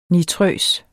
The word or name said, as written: nitrøs